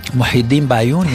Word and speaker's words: Muhyiddin Bayoun: Je vous propose d'écouter Muhyiddin Bayoun. Est-ce que je prononce comme il faut? – Muhyiddin Bayoun, oui.